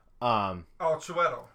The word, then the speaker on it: Chiwetel: How do you say it? Towie Ekafor, I don't know how to say his name. – Chiwetel Ejiofor.